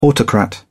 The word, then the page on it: autocrat: British English